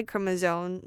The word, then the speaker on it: chromosome: Once activated, one of the genes on the Y chromosome, known as the SRY gene, has two functions.